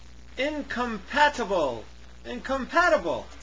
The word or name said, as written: incompatible